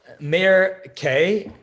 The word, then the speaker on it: Mayer Kay: So I'm Mayer Kay, aka Mayer Collinson, aka Mayer Kay. My stage name is Mayer Kay. It just rhymes with a great day.